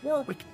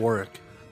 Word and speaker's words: Warwick: Oh, Willow is played by Warwick Davis. – He's an Elwyn. – You pronounced it incorrectly. – What? – Warwick. – It's Warwick. – Warwick Davis. I'm sorry.